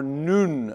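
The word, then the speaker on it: noon (νῦν): It is the Greek word N-U-N, nun or noon as it is pronounced.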